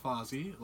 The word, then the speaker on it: Fozzie: My name is Fozzie, like the bear. Not quite like the Fonz, who's actually.